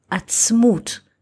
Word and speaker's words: atzmut: Another aspect is that the word bones in Hebrew, atzamot, atzamot, are not speaking about physical bones, but about the word atzmut, which is written in the same letters, but in different pronunciation.